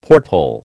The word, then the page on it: porthole: American English